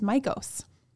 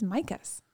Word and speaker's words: Mikos: And I always thought that it was Mycos, with an O, um, an o, o sound, and he pronounces it as Micus.